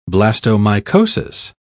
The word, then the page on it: blastomycosis: British English